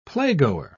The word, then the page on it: playgoer: British English